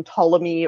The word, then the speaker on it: Ptolemy: You know, you don't pronounce the T in Ptolemy or the T in psychology.